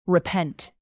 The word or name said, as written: repent